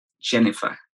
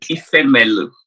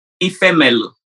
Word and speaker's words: Ifemelu: Here's the thing. Even though it's spelled differently, it's actually pronounced Jennifer. – It isn't. Stop it. – Ifemelu. – Stop it right now. Say it again. – Ifemelu.